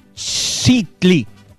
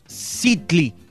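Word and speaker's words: tzictli: El nombre popular del chicle es de la palabra náhuatl citli, citli eh, se escribe con TZ cicli.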